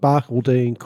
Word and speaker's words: Barcaldine: May 1st, 1891, the first May Day marches in Australia are held at Barcaldine? How do you pronounce that in Queensland? – I don't know. – Oh, okay. It held at Barcaldine, Queensland. That's exactly how you pronounce it.